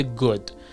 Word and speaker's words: good: اولا دقت کنید good باید تلفظ بشه، good تلفظ نمی کنیم. ببینید حالت او داره، یه اوی خیلی کوتاه. good خیلی تلفظ میکنن good، در حالی که good یعنی اوی کشیده نیست، در حالی که یه اوی خیلی کوتاه هست good.